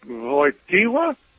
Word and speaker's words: Wojtyła: I said, does it sound like Wojtyła? He said, that's it.